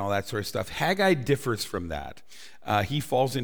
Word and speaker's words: Haggai: But before we do that, his name in Hebrew, Haggai, it's spelled with a C-H, and so it's Haggai. Haggai.